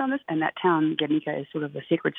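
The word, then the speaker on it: Gernika: So the Basque pronunciation of the town is Guernica.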